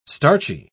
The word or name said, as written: starchy